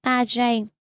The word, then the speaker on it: Target: Những người yêu thích Target thường gọi Target theo cách phát âm tiếng Pháp là Target.